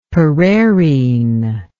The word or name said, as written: pereirine